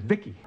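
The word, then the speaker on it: VICI: V-I-C-I, I, I pronounce it Vicky.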